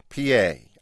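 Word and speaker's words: Piye: One of the pharaohs that you feature is, I believe I'm pronouncing this correctly, P.A. – Correct.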